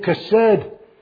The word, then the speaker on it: chesed: C-H-E-S-E-D. If you want to know how to say the k in the beginning, the chesed, remember the word bach. And chesed is said the same way.